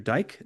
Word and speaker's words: Dyck: This one, Cicero, The Man and His Work by, I think it's, you pronounce it Andrew Dyck, Dyck.